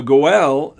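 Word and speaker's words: Goel (גואל): But once again, The Goel, that's how it's spelled in Hebrew, G-O-E-L, or how it's pronounced, rather, in Hebrew.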